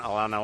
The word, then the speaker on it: Atlanta: Y es que Atlanta, Orlando lo pronuncian Alana Olano.